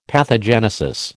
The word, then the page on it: pathogenesis: American English